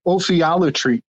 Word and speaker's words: ophiolatry: So a while back, something we do over on another channel on the spiders, we're really into this study of ophiolatry.